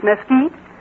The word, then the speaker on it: Mesquite: And it isn't Mesquite Springs, it's Mesquite.